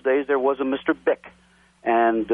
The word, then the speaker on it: Bich: The family name is spelled B-I-C-H, and for all the obvious reasons, they pronounced it Bick.